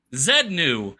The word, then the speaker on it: Xenu: It ends with a Z, just like Xenu. – That's right. It's pronounced Z, not Z. Z. – Zenu.